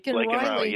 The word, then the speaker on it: Laken Riley: Is it Laken? – Mm-hmm. – Laken Riley. – Yes.